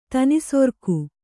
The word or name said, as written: ತನಿಸೊರ್ಕು